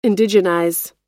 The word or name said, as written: indigenize